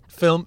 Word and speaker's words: film: Film production. There's not two syllables in film.